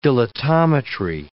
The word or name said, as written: dilatometry